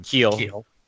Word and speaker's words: Kiel: Was this Richard Kyle or Kiel? How do you say his last name? – Kiel.